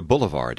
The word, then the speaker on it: boulevard: Here is the word for a boulevard in French. Listen and repeat, being sure you imitate the French pronunciation in every way.